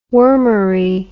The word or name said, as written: wormery